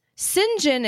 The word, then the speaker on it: St. John: So I wanted to see, since St. John is pronounced like the sin in Sinclair, but it's not spelled differently.